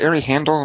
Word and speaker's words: Ari Handel: I think it's Ari Handel. Am I saying his name right? – Yeah, you're right.